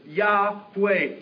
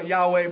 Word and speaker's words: Yahweh: we remember He is Yahweh, His very name, Jehovah Yahweh, by the way, same name. Like I said, we don't know how to pronounce it, so those two are both acceptable.